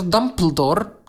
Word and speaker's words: Dumbledore: Oczywiście czyta się to Dumbledore, tylko że NT to jest po prostu D. D. Wymawia się.